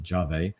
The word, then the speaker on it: Yave: It's spelled Yave, but apparently pronounced Jave.